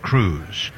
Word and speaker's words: Croes: And hitting ninth at first base, Dayson Cruz, C-R-O-E-S, pronounced Cruz.